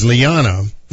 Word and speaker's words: Liana: His sister is Liana, not pronounced the American way, but not spelled, rather, the American way, but pronounced the American way.